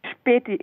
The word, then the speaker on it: Späti: Späti, it's pronounced Späti.